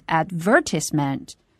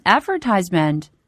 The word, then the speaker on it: advertisement: 在英音当中这个词读成 advertisement, advertisement, 它的重读音节落在第二个音节上。那么在美音当中它的重读音节落在第一个音节上，读成 advertisement, advertisement。